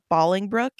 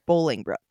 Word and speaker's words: Bolingbrook: And here, actually, I have to make a correction because it's not pronounced Bolingbrook. It's pronounced Bolingbrook.